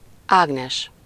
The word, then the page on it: Ágnes: /ˈaːɡnɛʃ/